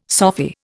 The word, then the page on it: Sophy: Indian